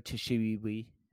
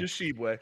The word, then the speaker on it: Tshiebwe: You have potentially, likely, probably, Emoni Bates, Drew Timmy, Chris Livingston, Oscar Tshiebwe. I probably butchered the hell out of his last name. – Tshiebwe. – Tshiebwe.